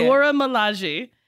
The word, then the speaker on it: Dora Milaje: How do you say it? – The Dora Milaje.